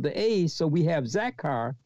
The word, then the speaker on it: zakar: Zakar, which is the word for remember, is spelled Z-A-K-A-R, Z-A-K-A-R, zakar.